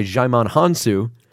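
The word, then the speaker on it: Djimon Hounsou: Korath, played by Jaimon Hansu.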